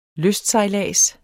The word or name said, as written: LYSTSEJLADS